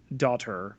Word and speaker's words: Dottir: Sylvie Loughy Dotter, that's spelled D-O-T-T-I-R, but of course, sounding like daughter, is a character in the 616, the very same day that this episode was released, Marvel Universe, also known, Matt, as...